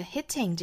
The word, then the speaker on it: hitting: 首先我们来看这个 hitting 这个单词。那我们注意里面的这个 t 的发音呢，英式发法会把它非常清晰的爆破出来，有一个 t。 Hitting, hitting.